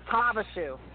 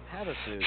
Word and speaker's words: Havasu: Havasu. – Havasu. How do you not know how to pronounce Havasu? It's H A V A S U.